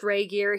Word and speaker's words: Regier: Well, that's why I wrote the pronunciation, because, you know, the way it's spelled, I wouldn't have been able to pronounce it, but yeah, it's pronounced Regier.